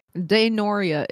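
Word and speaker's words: Daenorya: Denoria is how you pronounce it.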